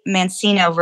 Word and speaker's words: Mancino: So you can send me an email at Mancino. I say Mancino versus Mancino for those who aren't familiar with the Italian pronunciation.